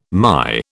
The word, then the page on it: MY: British English